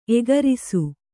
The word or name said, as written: ಏಕರಸ